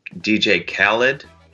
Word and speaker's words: DJ Khaled: And then the other day, back when I was a free Twitter user, I posted a joke about DJ Khaled. Is that how you say it? – Oh, yeah.